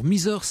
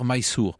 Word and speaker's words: Mysore: Alors, Mysore, c'est en fait la prononciation française d'une graphie anglaise. Il faudrait dire Mysore.